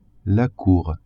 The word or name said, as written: cour